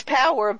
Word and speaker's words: PAWAR: It's power, but it's spelled P-A-W-A-R.